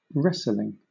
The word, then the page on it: wrestling: British English